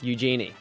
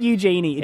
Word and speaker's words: Eugenie: Eugenie. – It's not Eugenie. – It is. – It's Eugenie. – It's a greeting that you'd say to a genie.